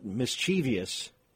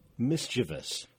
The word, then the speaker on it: mischievous: And if you say, well, of course, that's the word mischievous, it's only three syllables. It's not mischievous. It's just mischievous.